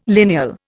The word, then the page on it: lineal: Indian